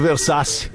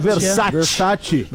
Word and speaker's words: Versace: Cara, tá me incomodando um pouco essa tua pronúncia do Versace. Eu acho que é Versace.